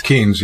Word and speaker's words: Keynes: Keynes, John Maynard Keynes.